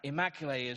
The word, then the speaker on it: Immaculée: Actually, it isn't even pronounced like that. Immaculée is, but I just couldn't get my mouth around how you pronounce her name.